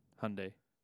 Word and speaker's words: Hyundai: Hyundai. – The Y is silent.